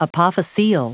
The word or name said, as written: apophyseal